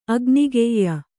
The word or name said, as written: ಅಗ್ನಿಗೆಯ್ಯ